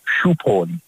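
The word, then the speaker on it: Shubh Holi: Als je wel iemand een gelukzalig holy wil wensen, dan zeg je shoep, shoep holy. Shoep holy prachtig uitgesproken, dat is hem, ja.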